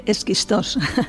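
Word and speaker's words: Schisto: Ritsona y Xitos, no sé si lo he dicho bien, y en la propia Atenas. – Hola Elena, muchas gracias. Sí, se dice Esquistos.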